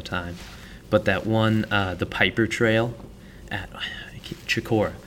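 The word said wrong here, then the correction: Chocorua: Chikorua. – Chikorua, is that actually how you pronounce it? – Yeah.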